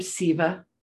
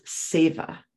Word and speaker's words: Seva: And I say her name Siva, but in Sanskrit, again, we're going back to pronunciation. Her name is Seva. You know, it's still spelled S-E-V-A, but I call her Siva. But Seva is service for the pure joy of service.